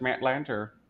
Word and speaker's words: Lanter: Like, hey, we said it correctly, it's Matt Lanter. – Oh, okay, cool. – I was like, I know it's Lanter or Lantern, and I didn't want to say Lantern if it wasn't, which is why I kind of let you say it, and then I went and checked on Google.